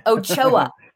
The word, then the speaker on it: Ochoa: Ochoa.